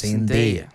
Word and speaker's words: Zendaya: Entonces sale Zendaya, porque ella dice que siempre dicen mal su nombre. Uh-huh. Mi nombre real es Zendaya. – Ah, ¿en serio? – Ella dice, siempre lo pronuncian mal, es Zendaya.